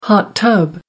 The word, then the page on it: hot tub: American English